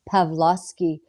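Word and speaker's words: Pawlowski: Art Pulowski has had an amazing win, and I'm saying his name wrong. It's Pavlovsky.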